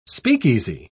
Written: speakeasy

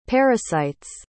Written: parasite's

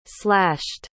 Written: slashed

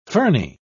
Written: ferny

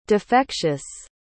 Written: defectious

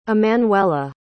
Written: emmanuella

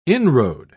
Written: inroad

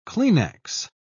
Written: Kleenex

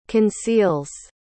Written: conceals

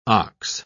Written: ox

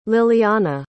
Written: liliana

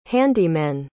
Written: handymen

